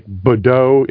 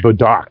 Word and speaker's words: Bordeaux: when it's like the French, when certain letters are at the end of the word, you don't pronounce them like Bordeaux. It wouldn't be Bordeaux.